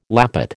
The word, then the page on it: lappet: American English